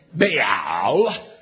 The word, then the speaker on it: Baal: And the word Baal is pronounced just that way. Baal. Baal. It is pronounced the same way in Greek. It's pronounced the same way in English.